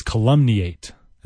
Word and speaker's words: calumniate: C-A-L-U-M-N-I-A-T-E. Calumniate. Or the noun is calumny. I always hate when a word has a different pronunciation in its noun than it does its verb form. You know, like the stress is different. Like calumny is on that first syllable, whereas calumniate is on that maybe second or third.